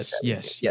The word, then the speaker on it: Thaddeus Young: Montrezl Harrell couldn't do anything with old head Thaddeus Young. – Yes. – Thaddeus Young, yeah, scoring, defense, everything you could possibly want from him.